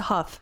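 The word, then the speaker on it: Hough: Huff. – It's a Huff. – Huff. Okay. His sister, who's also Dancing with the Stars, and also she starred in Footloose, Juliana Huff. – Yeah. Ah, Juliana Huff.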